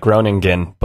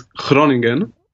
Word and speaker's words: Groningen: Because I'm gonna butcher it, but I would call it like Groningen, but that's clearly not correct. – Yeah, I mean that's how you write it with two G's, and the name is Groningen because the Dutch. There you go.